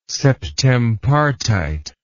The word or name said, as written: septempartite